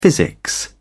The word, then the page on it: physics: British English